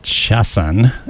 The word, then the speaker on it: Haxxan: Oh, That's a band which I believe is pronounced Chassan. It's spelled H-A-X-X-A-N.